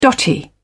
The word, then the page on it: dotty: British English